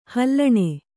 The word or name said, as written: ಹಲ್ಲಣೆ